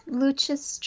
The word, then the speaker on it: Lucis: And Lucha, is that like Lucia or how do you spell that? – Oh, so it would be LUCIS Trust.